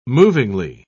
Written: movingly

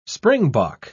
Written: springbok

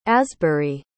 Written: asbury

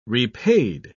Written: repaid

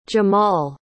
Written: jamaal

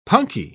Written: punkie

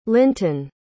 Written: lintun

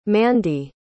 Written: mandie

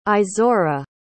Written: izora

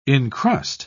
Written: incrust

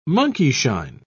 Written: monkeyshine

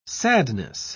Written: sadness

